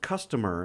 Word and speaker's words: CSTMR: Uh, their name is pronounced customer, and this is very much like Starfucker.